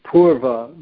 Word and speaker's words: purva: So paurava comes from the word purva, with a long U, purva meaning earlier or belonging to the past, with a long U. And then you can intensify it, paurava, with a, instead of a long U, an AU.